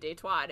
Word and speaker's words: Détroit: If it's the French pronunciation, it would be Détroit. It was settled by French trappers.